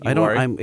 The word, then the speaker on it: Uwharie: We spent two days in, how do you pronounce this? Is Uhari? Uhari National Forest? Uhari? – Sure, yeah, I like Uhari. – It's spelled Uwharie National Forest.